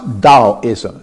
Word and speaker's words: Taoism: It's spelled with a T, but it's pronounced Taoism.